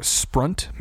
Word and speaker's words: sprunt: Can you tell me what sprunt means? Spelled like runt with an SP in front of it.